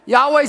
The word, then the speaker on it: Yahweh: Yahweh is the name of God of the Israelites, representing the biblical pronunciation.